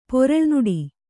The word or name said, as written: ಪೊರಳ್ನುಡಿ